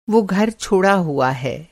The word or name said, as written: छोड़ा हुआ